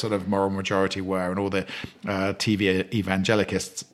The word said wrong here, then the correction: evangelists: How do you say that? TV evangelists were.